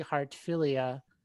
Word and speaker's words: Heartfilia: Standing outside is Lucy Heartfilia.